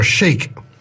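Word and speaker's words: Sheik: This is a real American accent with a song called Arkansas Chic. Not shake, it's S-H-E-I-K, but in this case it's pronounced chic.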